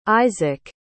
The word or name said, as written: Izaak